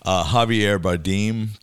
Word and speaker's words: Javier Bardem: So, Javier Bardem, did I pronounce his name right? – Uh-huh.